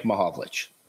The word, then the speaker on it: Mahovlich: The Hockey Hall of Famers like Red Kelly, Frank Mahalka, how do you say it? – Frank Mahovlich.